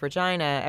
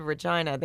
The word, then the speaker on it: Regina: He said vagina. I'm like, no, he said Regina. They just pronounce it wrong, unfortunately, in Canada.